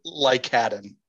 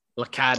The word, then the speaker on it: Lycadden: How do you say that one? – Lycadden. – Lycadden. – Like a Baden, but Lycadden.